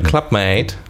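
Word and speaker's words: Club-Mate: Ah, weil es eben dann doch wahrscheinlich gewünscht ist, Englisch ausgesprochen zu werden. – Clubmate.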